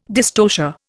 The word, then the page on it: dystocia: Indian